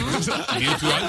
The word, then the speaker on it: Mutual: Mutual, lo he dicho bien. – Lo has dicho muy bien.